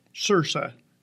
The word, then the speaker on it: Saoirse: Saoirse. It rhymes with inertia.